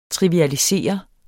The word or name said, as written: TRIVIALISERE